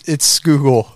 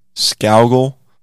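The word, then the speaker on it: Scougal: O-U-T. – O-U-T. So why is Scoogle's name S-C-O-U? That would be owl then, right? Scowgle. This whole time, everybody's saying Scoogle, and he's in the grave like, no, it's Scowgle.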